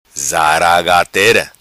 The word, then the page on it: Zaragatera: patua